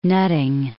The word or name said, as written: Nutting